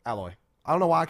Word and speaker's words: Aloy: I'm going to say Alloy. – Nope. – Aloy, technically, how you say it, but the reason never mind.